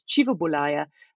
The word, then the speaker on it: Kivibulaya: And for any Luganda speakers out there, his name is often pronounced in Luganda as Kivibulaya, although Swahili and other East African language speakers tend to give it a hard K.